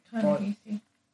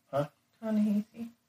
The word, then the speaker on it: Ta-Nehisi: Captain America by Ta-Nehisi Coates. I don't know if I said that right. – Ta-Nehisi. – Ta-Nehisi.